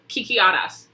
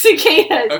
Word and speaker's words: cicadas: Is that how you pronounce it? – Kikiadas, actually. – Kikades. – Are you serious? – Is it cicadas? – Yes, it's cicadas.